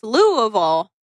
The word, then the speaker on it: Louisville: Louisville. No, it's Louisville.